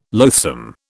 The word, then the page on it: loathsome: British English